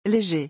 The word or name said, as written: leger